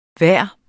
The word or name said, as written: hver